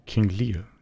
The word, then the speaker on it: Lear: Eine Geschichte hieß die Uhr, eine war, war eine Aufarbeitung von, oder eine Umsetzung des Stoffs von König Lear, spricht man den so aus, also das Shakespeare-Stück. King Lear, ja.